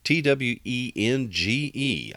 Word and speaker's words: Twenge: This book is by a psychologist by the name of Jean Twenge, and that's uh, spelled T W E N G E. I had to uh, I had to go ask her publicist how to pronounce that, but it's pronounced Twenge.